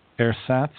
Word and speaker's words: ersatz: Your false self, your ersatz self, let me pronounce that better, ersatz self, can never satisfy you in the long term.